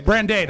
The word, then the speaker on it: brandade: Is that pronounced brandade? Is that brandade? – Brandade, I believe.